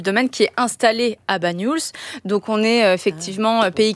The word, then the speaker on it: Bagnols: Comment on le prononce? – Bagnols. Oui, ouais. C'est ça.